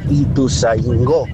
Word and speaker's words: Ituzaingó: A la periodista que estaba hablando sobre el tiempo ahí, sobre las calles, se dice y se escribe Ituzaingó.